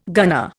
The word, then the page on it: gunner: Indian